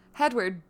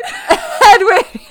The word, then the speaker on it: Hedwig: Hedwig. – You didn't even say it right. – Hedwig.